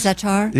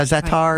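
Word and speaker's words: za'atar: Zatar. – Yeah, zatar. Right. Is that how you say it? – Yes.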